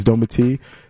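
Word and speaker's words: Dometi: My name's Doma T. That's D-O-M-E-T-I.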